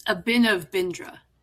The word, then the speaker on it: Abhinav Bindra: It's Abhinav Bindra.